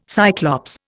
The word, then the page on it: Cyclops: Indian